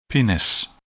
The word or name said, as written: penis